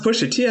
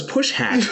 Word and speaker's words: Pusha T: Oh, wait a minute, it's pronounced Pusha T. I thought it was Push Hat.